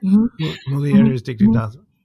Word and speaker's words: Mulieris Dignitatem: Am I going to butcher it? Mulieris Dignitatem. Is that right? – Mulieris Dignitatem.